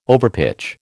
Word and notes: overpitch: American English

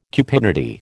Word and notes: cupidity: American English

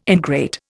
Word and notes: ingrate: Indian

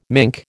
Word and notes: mink: American English

